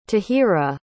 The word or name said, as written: tahira